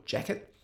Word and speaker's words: Jacquet: So the saxophone, of course, is Jean-Baptiste Illinois Jacquet, or Jacquet, if you pronounce with the American pronunciation.